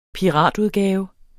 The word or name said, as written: PIRATUDGAVEN